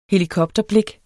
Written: helikopterblik

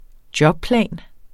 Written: jobplan

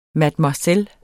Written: mademoiselle

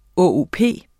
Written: ÅOP

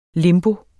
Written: limbo

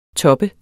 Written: toppet

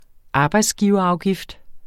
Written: arbejdsgiverafgift

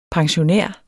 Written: pensionær

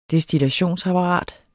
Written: destillationsapparat

